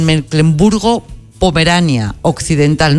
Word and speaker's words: Mecklemburgo-Pomerania Occidental: Mecklemburgo-Pomerania Occidental. No sé si lo he dicho bien. Rosalía Sánchez, corresponsal, buenas tardes. – Buenas tardes, lo has dicho perfectamente.